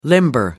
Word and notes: limber up: American English

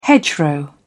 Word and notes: hedgerow: British English

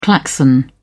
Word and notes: klaxon: British English

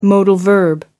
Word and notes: modal verb: British English

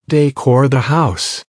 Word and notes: decor the house: American English

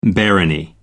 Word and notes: barony: American English

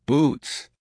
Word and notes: boots: American English

